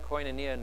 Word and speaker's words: koinonia: The Greek word here is koinonia. Sorry, I always get it mixed up. Strange thing, in South Africa it's pronounced differently. So, you know, Greek is pronounced differently. Koinonia, thank you.